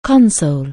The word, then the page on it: console: anglais